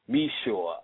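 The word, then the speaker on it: Micheaux: Oscar Meshaw. His last name is spelled Michaux.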